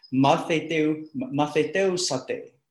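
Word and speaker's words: matheteusate (μαθητεύσατε): If you really want to hear the Greek word, it's pronounced matheteusate.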